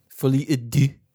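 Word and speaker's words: Folie à Deux: Is that how you're going to say it for Joker 2? Folie et Deux. – I mean, that's how you say it.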